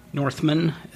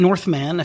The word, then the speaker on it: Northman: Northman, as most people would say. But Northman, if you want to emphasize both syllables in a compound word.